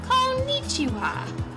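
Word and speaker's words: Konnichiwa (こんにちは): So I will say it a few times. Hello. Konnichiwa. And slowly, konnichiwa.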